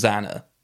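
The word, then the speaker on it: X.A.N.A.: Xana, it's spelled X dot A dot N dot A dot. So it's an acronym, but spells Xana.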